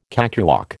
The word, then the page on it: cakewalk: American English